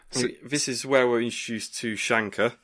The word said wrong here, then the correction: Sankar: I think I've got his name right. Let me just check IMDb. – Sankar. – Why did I put an H in there?